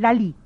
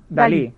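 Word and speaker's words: DALL-E: Eh, Dal E. – Dal E. – Dal E. – Es pronunciado Dal, Dalí. – Dalí.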